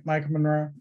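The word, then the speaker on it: Maika Monroe: Am I saying her name right? Micah Monroe? – Yes. Yeah, Micah.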